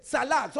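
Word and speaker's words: tsalach: And the word prosper there is salak. Someone said salak. – Salak. – Salak is spelled T-S-A-L-A-C-H. T-S-A-L-A-C-H. Salak means to receive a mighty push forward.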